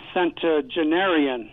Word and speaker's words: centenarian: if the person lives to become a centenarian. I don't know if I said that right, but a 100-year-old person. – You did say it right.